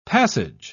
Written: passage

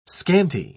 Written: scanty

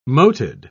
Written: moted

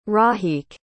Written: rahiq

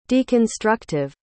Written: deconstructive

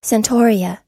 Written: centaurea

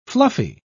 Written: fluffy

